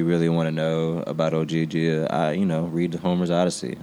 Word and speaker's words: Ogygia: Ogygia, yeah, which is an island in Homer's Odyssey.